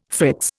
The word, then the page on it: fritz: Indian